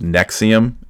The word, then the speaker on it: NXIVM: I kept seeing this weird word that I didn't know how to pronounce, which is Nexium, NXIVM.